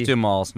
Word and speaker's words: Duhamel: Josh Duhamel's new girlfriend. – Good for him. – Duhamel. – Is that how you say it? – Uh-huh.